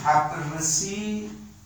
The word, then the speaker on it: طبرسی: نه، طبرسی معمولا تلفظ صحیح‌تریه.